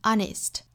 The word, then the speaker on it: honest: 那我们要注意里面有一个小词，就是 honest 这个词，它虽然是辅音字母打头，但是我们要注意它其实发音来说是元音打首的 honest。